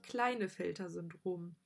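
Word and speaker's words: Klinefelter: Weil ich hatte gedacht, dass man sagt Klinellefelter-Syndrom oder so ähnlich, aber du hast mich gerade schon äh, korrigiert. Es heißt nämlich Kleinefelter-Syndrom.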